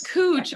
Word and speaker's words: Couch: There is a street in downtown Portland and a lot of stuff that is spelled C-O-U-C-H. And so I would go around and ask people like, hey, that's where are we on Couch Street? Well, it's Cooch. Apparently, it's like a guy who's been like around here and is very well known, and that's how they can tart.